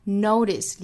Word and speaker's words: notice: Might 尾部 t 略掉，notice 里面的 t 的音更多弹出来，notice 的 d 像一个 d 的发音，notice notice 非常轻快的 d 的音。